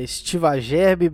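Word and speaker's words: Estiva Gerbi: Então aí essa conexão é, Estiva Gerbe, Belo Horizonte. É, falei certo? – Falou, isso mesmo.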